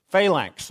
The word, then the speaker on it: phalanx: Am I saying that right? Phalanx? Phalanx, thank you.